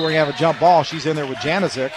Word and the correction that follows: Janosik: I think it's Janosik. – Janosik. – She pronounced it to me.